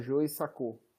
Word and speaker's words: Joe Sacco: É Joe, J-O-E-S-A-C-C-O. Acho que a pronúncia é essa, Joe Sacou.